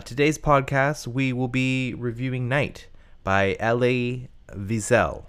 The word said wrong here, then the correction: Elie: I thought it was Ellie. – It is Ellie. I just said it wrong.